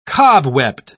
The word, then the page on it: cobwebbed: British English